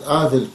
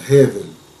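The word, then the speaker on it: Гевель (הבל): Все знают Авель, Каин, но это не еврейское произношение. Каин совпадает, а Авель нет. Правильное его имя было Гевель. По-русски тяжело, я понимаю, потому что буквы Гей, звука Гей нету. Но мы должны говорить правильно. Гевель.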